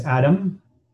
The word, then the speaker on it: Adam: My name is Adam, ADAM.